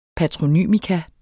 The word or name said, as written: patronym